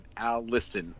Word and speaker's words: allicin: It's A-L-L-I-S or I-C-I-N, allicin, which is a pungent, sulfur-containing molecule similar to the one in wasabi.